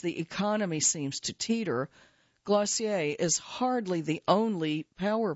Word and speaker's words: Glossier: That is what has catapulted Glossier, pronounced like dossier, into the pantheon of unicorns, the tech-speak term for startups worth a cool 10 figures.